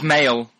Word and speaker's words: Miele: I don't know how to pronounce it. It's honey in French, isn't it? – It's male.